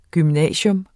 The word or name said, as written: gymnasium